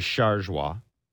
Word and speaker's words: Chargois: The real way to pronounce it is Chargois. Chargois.